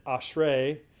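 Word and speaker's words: ashrei (אַשְׁרֵי): And the Hebrew word for blessed, in this case, is the word esher. Now, Tim pronounces it ashrei, and since he's the Hebrew scholar, I'm going to defer to him.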